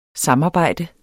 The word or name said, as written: SAMARBEJD